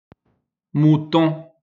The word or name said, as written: mouton